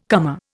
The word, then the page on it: comer: Indian